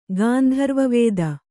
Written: ಗಾಂಧರ್ವವೇದ

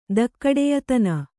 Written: ಧಕ್ಕಡೆಯತನ

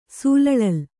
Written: ಸೂಲಳಲ್

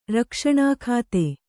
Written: ರಕ್ಷಣಾಖಾತೆ

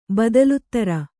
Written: ಬದಲುತ್ತರ

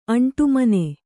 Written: ಅಂಟುಮನೆ

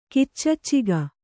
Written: ಗುಚ್ಛಕ

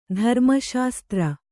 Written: ಧರ್ಮಶಾಸ್ತ್ರ

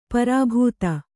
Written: ಪ್ರಾಭಾತ

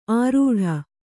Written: ಆರೂಢ

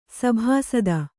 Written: ಸಭಾಸದ

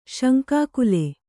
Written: ಶಂಕಾಕುಲ

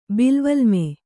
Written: ಬಿಲ್ವಲ್ಮೆ

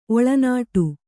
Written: ಒಳನಾಡು

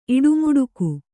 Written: ಇಡುಮುಡುಕು

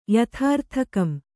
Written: ಯಥಾರ್ಥಕಂ

